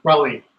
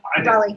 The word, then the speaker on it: Raleigh: Raleigh. – Raleigh. You said it right. Raleigh.